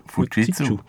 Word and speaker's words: Fujitsu: Fujitsu ist so die, die wollen ich aussprechen, Fujitsu. Wie sagst du denn? – Fujitsu. – Ja, wie Fuji und ja, dann die zu.